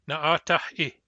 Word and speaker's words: na'atah-i: The command, you listen, na'atah-i. Na'atah-i. Na'atah ends in a back H, which is not a hardenable sound. Therefore, the hardening is pronounced as a glottal stop.